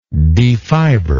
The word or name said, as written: defiber